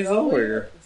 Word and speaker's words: Zellweger: Renee Zellweger. – How do you say that? – Zellweger, I believe is how it's pronounced.